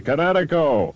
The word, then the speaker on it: Kinetico: Everybody knows that Kinetico is spelled K-I-N-E-T-I-C-O. – I thought the instructor said Connecticut on the East Coast. – Son, you should have remembered kinetic as in kinetic energy with an O on the end. Kinetico. – Kinetico. Spell it K-I-N-E-T-I-C-O.